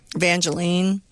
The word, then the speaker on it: Evangeline: Is it pronounced Evangeline or Evangeline? – Evangeline.